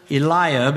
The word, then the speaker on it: Eliab: Came to pass when they were come that he looked on Eliab is how that word is pronounced, and said, "Surely the Lord's anointed is before him."